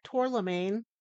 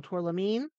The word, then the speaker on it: tourmaline: Use grounding stones like, I'm going to say it wrong again, black tourmaline. I know that's wrong, tourmaline, and hematite.